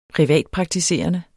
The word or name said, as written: privatpraktiserende